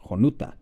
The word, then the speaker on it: Jonuta: As we move into the eastern part of Tabasco and into the municipality of Jonuta, we find the site of Jonuta, spelled with an X, although it is pronounced the same as the municipality, which is now spelled with the Latinized J.